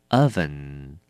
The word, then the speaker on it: oven: 第三个字 oven, oven, 名词烤箱，这个字呢一定要小心它的发音，它是念成 oven oven，千万不能念成 oven 或者是 oven，都是不对的，要念成 oven。